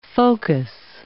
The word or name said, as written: focus